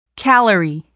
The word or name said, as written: calorie